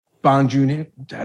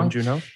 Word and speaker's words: Bong Joon Ho: Like it really is better for all of us if you have a Chloe Zhao, if you have a uh, a Bong Joon, how do you say his name? Director Bong, Bong Joon Ho.